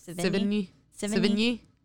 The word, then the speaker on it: Sevigny: Versus Chloe Sevigny. How do you say her name? – Sevigny. Yeah.